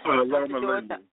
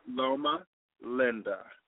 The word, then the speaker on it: Loma Linda: Loma Linda. – Say it one more time. – Loma Linda. – Yeah, Loma L-O-M-A.